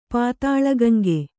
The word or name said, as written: ಪಾತಾಳಗಂಗೆ